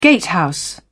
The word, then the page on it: gatehouse: British English